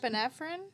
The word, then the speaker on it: norepinephrine: So in this case, with ADHD, the neurotransmitter is called Pinephreen, saying it wrong probably, but it's also the first. – Norepinephrine? – God damn it. Yes.